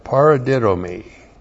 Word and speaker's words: paradidomi: P-A-R-A-D-I-D, long O-M-I, paradidomi.